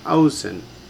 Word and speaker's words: ozen: And the first word you have accents on the first syllable, and it's simply ozen.